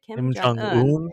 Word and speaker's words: Kim Jong-un: Kim Jong-un, I think.